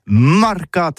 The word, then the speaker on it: marcat: Eh bien, disons à tous, mais le mercat de l'Uni ou le mercat des rebelles, mais en gascogne toulousain, c'est prononcé sur les marcats, avec un A marcat.